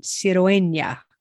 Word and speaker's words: Cirueña: See, when we get so many vowels, sometimes it's hard to figure out where to put the accent. Cirueña. So it's the second to last syllable.